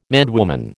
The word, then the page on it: madwoman: American English